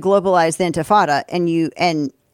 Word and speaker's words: intifada: I think it's pronounced the intifada. I think you're saying it. – Did I say it correctly? – I think you're saying infantata. – Oh, I did. Intifada, yes. Intifada.